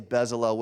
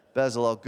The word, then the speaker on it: Bezalel: Everybody say Bezalel with me so I don't feel bad. Bezalel, good.